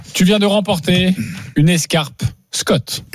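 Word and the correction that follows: Schott: Oui, Shot et pas Scott, ça fait des années qu'on prononce mal la marque.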